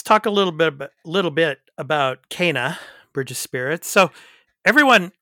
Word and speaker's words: Kena: Okay, well, in this game, the main character's name is pronounced K, like in the letter K, Kena.